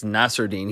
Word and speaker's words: Nasirildeen: And this prospect from Florida State, and it looks like his name is pronounced Nasirildeen. Looked it up last night. It's Nasirildeen. He said it in an interview, apparently.